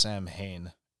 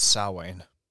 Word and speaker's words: Samhain: it's not pronounced Samhain, it's Samhain. They spelled it right, but because it's originally a Celtic or Irish word, if you prefer, it's not really pronounced how it's read. So it's Samhain instead of Samhain.